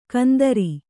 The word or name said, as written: ಕುಂದ್ರಿ